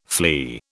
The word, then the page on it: flea: British English